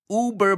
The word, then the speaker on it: Uber: Yes, so in English sometimes we say, oh, that thing is uber big.That also means super big. So Uber, in this case, is pronounced like that because that's how the German word stands in English.